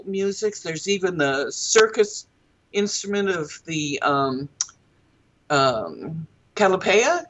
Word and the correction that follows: Calliope: is that how you pronounce it? – Calliope, I believe, is what it's called. Calliope, yes, of course that is.